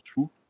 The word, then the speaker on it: Xu: Donc euh, XU pour mais ça je crois que ça se prononce Shu.